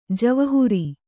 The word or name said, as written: ಜವಾಹಿರು